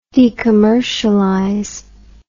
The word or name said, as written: decommercialization